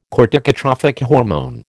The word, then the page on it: corticotrophic hormone: American English